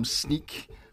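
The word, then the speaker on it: Snyk: Today, my guest is Matt Jarvis from Sneak. I always say that name wrong, so I emphasize it just so that I get it right.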